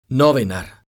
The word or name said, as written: novinar